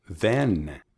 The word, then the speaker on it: then: Them, con labios abiertos. Ah, then, then. – Correcto, then. Entonces, then. Tengo los labios abiertos y la lengua tocando las encías detrás de los dientes en la frente de la boca.